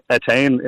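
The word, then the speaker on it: Étaín: I'm familiar with some children who have that name, and it's usually pronounced Etain in Irish English.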